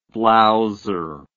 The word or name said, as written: blowser